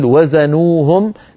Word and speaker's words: وزنوهم: فلا تفصل نطقا، يعني ليس لقارئ أن يقول وزنوهم يخسرون، ليس لأحد أن يفعل ذلك، لابد أن يقول وزنوهم بوزن فعلوهم.